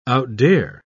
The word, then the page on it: outdare: British English